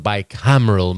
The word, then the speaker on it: Bicameral: I'm joined by Jessica, and we are closing up shop on season one of Westworld with the episode The Bicameral Mind. I pronounced it right that time.